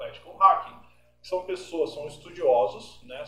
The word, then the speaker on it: ethical hacking: É assim que se pronuncia mesmo? É assim mesmo. Ethical hacking.